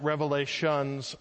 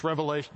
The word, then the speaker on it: Revelation: The only view you can't have is you can't pronounce it Revelations plural, because that's not the name of the book. It's Revelation. There was one Revelation. Revelation and Awana are both singular.